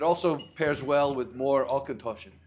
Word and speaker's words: Auchentoshan: And this one is pronounced Auchentoshan.